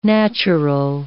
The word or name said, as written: natural